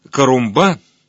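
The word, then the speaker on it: Corumbá: Ближайший город Карумба, с ударением на последнем слоге, я не смог найти ее.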